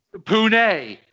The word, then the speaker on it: Pune: Pune is how you pronounce it.